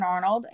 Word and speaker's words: Arnold: It's Katherine Arnold.